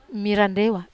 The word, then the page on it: marandewa: Wamesa